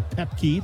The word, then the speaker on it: Pepke: Shane Willenbring got downfield, as did on that left-hand side Will Popke. – Popke. – Actually, Pepke, the official pronunciation for the Rapid City native.